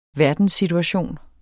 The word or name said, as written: verdenssituation